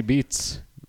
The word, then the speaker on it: Beetz: Zazie Beetz. – Is it spelled like beets? Like you eat them? They grow from the ground? – It's spelled like beets, but with a Z.